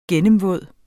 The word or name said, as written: gennemvåd